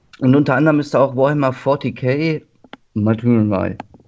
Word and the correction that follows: Martyr: Ich weiß nicht, wie es ausgesprochen wird. Matter. – Martyr. – Martyr. – Achso.